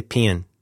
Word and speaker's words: paean: how do you say paean? Well, that's not making it sound like penis. How do you say that? It's Greek. It's got a P-A-E in it. Tribute. – Paean. – Pain.